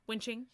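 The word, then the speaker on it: Wen Qing: So here was Skye's theory about when Ching, is that how you would say her name? It's a Q. So Wen Ching? – Yeah.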